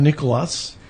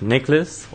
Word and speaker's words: Nicolas: And then we have Nicolas. – So, hi, uh, my name is Nicholas or Nicolas. It depends on whether you pronounce with the the, uh, the American way or the French way.